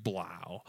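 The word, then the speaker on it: Blough: How do you say that last name? – Blau. – Blau. Blau. Yep.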